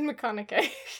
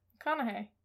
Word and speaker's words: McConaughey: You said McConaughey. – Well, how do you say it? – McConaughey. – Yeah, that fella.